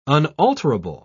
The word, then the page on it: unalterable: British English